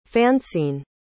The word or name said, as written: fanceen